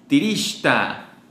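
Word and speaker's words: Trixtá: Shun, S-H-U-N, querida o amante. Siguiente, Trixta. Trixta, sí, Trixta, con acento la A y X, pero la X se pronuncia S-H, entonces sería Trixta. Traigo Trixta.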